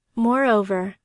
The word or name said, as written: moreover